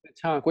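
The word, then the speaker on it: Pétanque: Petanque, which is like uh, you know bocce.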